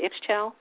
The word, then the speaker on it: Ixchel: Is that how you say it, Ixchel? – Yes.